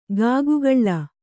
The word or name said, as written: ಕಿಗ್ಗಳ